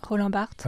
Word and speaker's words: Barthes: Roland Barthes.